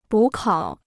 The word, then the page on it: 补考: bǔ kǎo